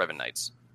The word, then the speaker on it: Revanites: Not like the Revanite, the Revankiss, the Revanites. It's a more, you know, accurate pronunciation, the Revanites.